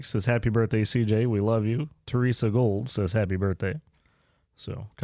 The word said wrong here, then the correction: Gould: It's Gould. – Gould. Yeah, Gould. Pronounce it.